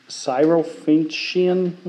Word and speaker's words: Syrophoenician: The Syrophoenician woman? Is that how you pronounce it? Thank you.